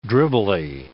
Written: dribbly

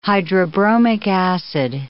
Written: hydrobromic acid

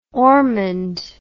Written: ormonde

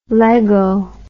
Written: lego

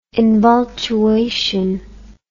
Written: invultuation

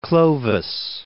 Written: Clovis I

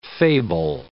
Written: fable

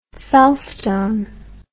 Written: felstone

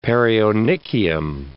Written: perionychium

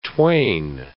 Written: twain

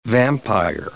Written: vampire

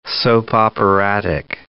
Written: soap-operatic

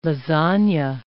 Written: lasagne

